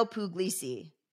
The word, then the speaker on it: Puglisi: And so this one post is about, I can't pronounce this man's name, but it's about Pino Puglisi? – Giuseppe Pino Puglisi.